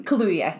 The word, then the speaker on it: Kaluuya: I think in the end, I think Daniel Kaluuya still wins. Am I pronouncing it? – Kaluuya. – Kaluuya. Okay.